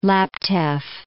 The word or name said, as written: Laptev Sea